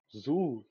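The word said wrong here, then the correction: Zhou: que se pronuncia. Como é que é? Joe. Gu, Joe, Joe.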